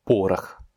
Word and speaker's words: порох: Порох. Là encore, le deuxième O n'est pas accentué, il se prononce donc comme un A.